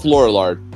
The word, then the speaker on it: Lorillard: Laura Lord? Laura Lord? How do you pronounce that? Laura? I'm not from 1789, so I can't pronounce it. – Laura Lord? We'll go with Lorillard.